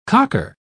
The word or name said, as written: cocker